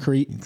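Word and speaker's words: Kriet: Isn't it Crete? – Jeff Crete.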